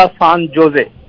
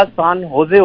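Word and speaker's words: San José: उसने कहा सान जोजे। – सान जोजे? – सान जोजे नहीं होता, सान होजे होता है। हम स्पेनिश में जे को एच की प्रोनाउंस आवाज निकालते हैं, तो ये वो सान होजे।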